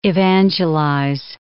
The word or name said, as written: evangelize